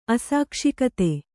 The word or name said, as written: ಅಸಾಕ್ಷಿಕತೆ